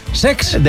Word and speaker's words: Cesc: Lo he dicho mal, es Sex.